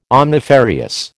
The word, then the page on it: omnifarious: American English